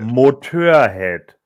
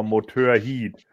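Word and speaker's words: Motörhead: Obwohl eigentlich ist es ja, wenn die Leute das dann noch falsch aussprechen auf Wacken, dann war es ja immer Motorheat.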